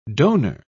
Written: donor

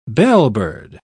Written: bellbird

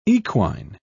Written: equine

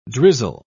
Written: drizzle

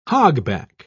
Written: hogback

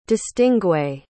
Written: distingué

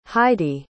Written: heidy

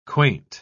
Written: quaint

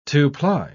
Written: two-ply

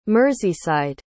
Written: merseyside

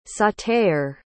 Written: sauter

